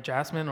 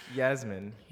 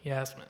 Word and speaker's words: Yasmin: Well, I mean, obviously I wasn't here, but I know how to pronounce her name, Jasmine, or because it's with a Y. So yeah, we said it a trillion times. Yasmin. – Yasmin. There you go. Yasmin.